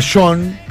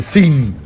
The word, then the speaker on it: Sean: Y es la canción dedicada a su segundo hijo, que se llama John, pero no John, Sean, como Sean Connery. ¿Viste ese que se escribe Sean? Que algunos le dicen Sin, pero es John, en realidad la pronunciación, y creo que es un nombre de origen escocés, pero no tengo la más mínima idea.